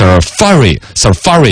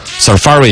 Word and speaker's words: Safari: 他还专门搜了一个这个英语字典那个出来，实际的发音应该是 Safari 应该是这样子读，嘎。 Safari 应该是重音在第二个，好像我之前是有印象的 Safari 浏览器，苹果的浏览器 Safari，英式和美式的发音呢，啊稍微长一点，短一点，英式的要长一点 Safari 和 Safari 听起来差不多了，我要转一点回来，表示我也懂啊。